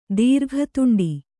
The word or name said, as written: ದೀರ್ಘತುಂಡಿ